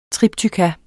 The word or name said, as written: triptykon